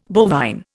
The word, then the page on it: bovine: Indian